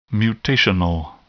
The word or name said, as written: mutational